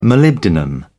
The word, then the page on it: molybdenum: British English